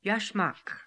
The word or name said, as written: yashmak